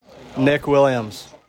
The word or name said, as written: Williams